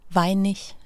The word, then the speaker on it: Weinig: Ja, Weinig hedder han faktisk. – Weinig? – Ja, altså man staver det med G, øhm, men man udtaler det som ich, Weinig.